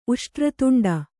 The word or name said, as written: ಉಷ್ಟ್ರತುಂಡ